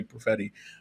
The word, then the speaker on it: Perfetti: And Lisa Ann Walter and Chris Perfetti, that's how we say his name, Perfetti.